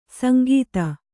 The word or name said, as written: ಸಂಗೀತ